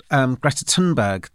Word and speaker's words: Greta Thunberg: So you talked about Greta Thunberg.